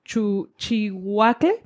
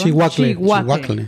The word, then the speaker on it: Chihuacle: Sin embargo, algo muy curioso entre el mole negro es que hay un chile en específico que se pronuncia Chihuacle, perdón. Chihuacle. Chihuacle.